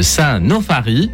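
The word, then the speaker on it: Saint-Nauphary: Boulanger, je vais mal prononcer, mais c'est pas grave, boulanger de Saint-Nofari. Ça va, j'ai bien dit.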